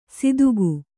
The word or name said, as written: ಸಿದುಗು